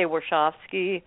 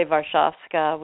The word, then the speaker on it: Warshawski: Well, she's American, and so it's an Americanized pronunciation. I say Warshawski. I don't say Warszawska, which is what it would be if she was really sure.